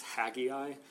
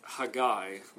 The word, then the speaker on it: Haggai: Here, he quotes from the prophet, I always struggle to say this, I was taught to pronounce this Haggai, but it's Haggai, literally.